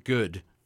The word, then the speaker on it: Guud: And before then, we heard two tracks from an album, June of last year, Good. But G-U-U-D, so I can only pronounce it good.